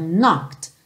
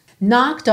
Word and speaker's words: knocked: Honey, when you knocked on my door, não é knocked, lembra que esse E tá pintadinho de cinza, não vai ser pronunciado, então knocked, knocked on my door.